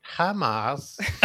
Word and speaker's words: HIMARS: I actually have no idea how people pronounce this acronym. Is it HIMARS? – HIMARS.